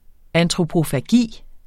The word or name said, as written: antropofagi